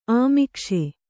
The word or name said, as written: ಅಮೀಕ್ಷೆ